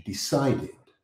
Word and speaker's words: decided: The stress in the word decided is in the middle, in the CI combination, decided, decided, not decided.